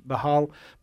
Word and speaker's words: Baal: You know, Baal or Baal is what they say, I guess, in the Jewish folks actually say that's how you pronounce it, Baal.